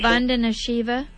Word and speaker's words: Vandana Shiva: Vandana Shiva.